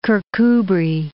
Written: Kirkcudbright

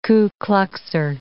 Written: Ku Kluxer

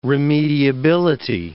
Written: remediability

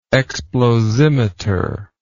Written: explosimeter

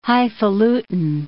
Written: highfalutin

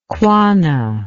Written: quanah

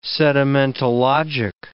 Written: sedimentologic

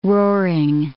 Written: roaring

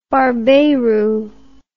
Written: barbeiro

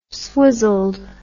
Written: swizzled

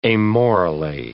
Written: amorally

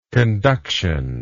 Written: conductional

